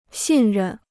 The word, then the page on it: 信任: xìn rèn